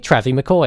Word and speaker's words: Travie McCoy: Travie McCoy.